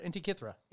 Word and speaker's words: Antikythera: I said it wrong. – No, I think that was right. Antikythera.